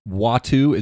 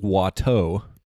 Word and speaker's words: Watto: He told me over the week that Watu is actually pronounced Watto.